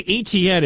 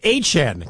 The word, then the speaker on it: Etienne: New way to say ETN. It's HN, kind of like HN in Miami, but it's spelled ETTIENNE, HN.